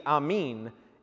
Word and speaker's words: Amen: It's pronounced actually Amen, and it means, so be it.